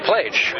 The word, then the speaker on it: Plage: Here comes that erstwhile legal worker, Steve Plage, rhymes with age.